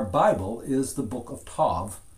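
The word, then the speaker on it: tov (טוב): It's pronounced actually tov, looks like T-O-V, and literally means good or goodness.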